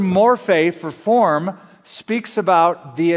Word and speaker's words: morphe: The word form that is used in this translation, who being in the form of God, is the Greek word morphe, morphe. We would spell it in English, M-O-R-P-H-E.